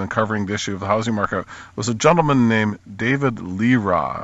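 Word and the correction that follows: Lereah: I don't know if that's the correct pronunciation. – Laria.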